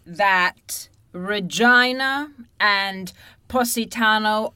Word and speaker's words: Regina: It, of course, is the butt of many jokes, being that the city is pronounced Regina. There's a famous joke saying Regina, the city that rhymes with fun.